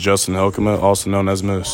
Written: JUSTIN HYLKEMA

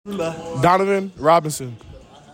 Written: DONOVAN ROBINSON JR

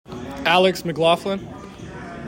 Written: ALEX MCCLAUGHLIN

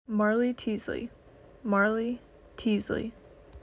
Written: Marley Teasley

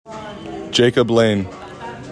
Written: JACOB LANE

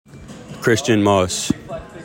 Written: CHRISTIAN MOSS

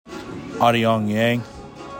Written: AH DEONG YANG

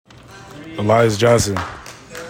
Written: ELIAS JOHNSON

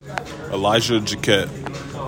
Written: ELISHAH JACKETT

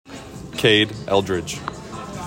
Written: KADE ELDRIDGE

